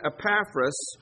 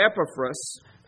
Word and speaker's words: Epaphras: He mentions him in the first chapter and again in the second chapter, and it's this person Epaphras or Epaphras. I've heard it pronounced both ways, and Epaphras is a co-minister with Paul.